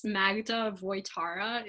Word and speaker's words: Magda Wojtara: It's Magda Wojtara is how it's pronounced, and it would be M-A-G-D-A and then W-O-J-T-A-R-A.